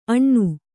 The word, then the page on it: ಅಣ್ಣು: aṇṇu